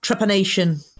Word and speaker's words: trepanation: I'm gonna pronounce this right. Trepanation? – Trepanation or trepanning? Trepanation.